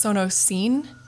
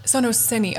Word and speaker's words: Sonociné: So I said it wrong. It's Sonociné. – Sonociné.